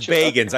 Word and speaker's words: Bagans: Yeah, you know, I don't know how to pronounce it. – He says Bagans.